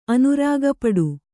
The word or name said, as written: ಅನುರಾಗಪಡು